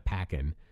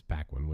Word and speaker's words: Paquin: I always want to say Anna Paquin but it's not. It's Paquin, which, you know, makes sense because that's how it's spelled.